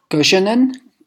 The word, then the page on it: Göschenen: German